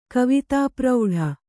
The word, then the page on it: ಕವಿತಾಪ್ರೌಢೆ: kavitāprauḍhe